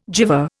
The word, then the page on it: gyver: Indian